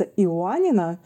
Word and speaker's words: Иоаннина: Янина, город Янина. – Янина. Я потом погуглю обязательно. – Да, по-гречески он называется Иоаннина. Это тоже интересно. Студенты узнают, что их город как-то по-другому читается по-русски и пишется.